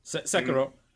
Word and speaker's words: Sekiro: Sekiro Shadows Die Twice. – Sekiro.